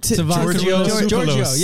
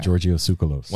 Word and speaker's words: Giorgio Tsoukalos: Giorgio Tsoukalos. – Yeah, yeah, that's it. You're going to win that round. All about all right. Giorgio Tsoukalos.